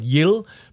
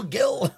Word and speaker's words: Gil: It's in outside Brussels, a place called Yil, but it's spelled G-I-L. It's Dutch. It's like Flemish. And they go, I'm like, "Hello, Gil." And they're like, "Yil! Stop yelling! Why are you yelling?"